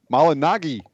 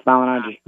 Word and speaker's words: Malignaggi: And now, coming to you live, it's Paulie Malignaggi. Did I say it right, Paulie? – Nah, it's Malignaggi.